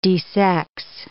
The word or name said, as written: desex